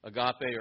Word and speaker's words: agape: One word is agape. Have you heard of it before? Agape or agape, wherever you put the, the emphasis on the right syllable.